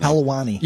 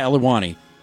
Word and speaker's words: Helwani: Helwani. – Yeah, Helwani.